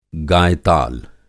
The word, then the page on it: गायताल: Hindi